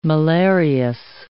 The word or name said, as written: malarious